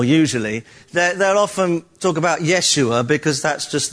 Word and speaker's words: Yeshua: It's really just a transliteration of sounds like the original word of the Hebrew word, which we would pronounce now Joshua, more correctly Yeshua, if you're speaking it in a more Hebrew kind of way.